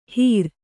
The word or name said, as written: ಹಿರ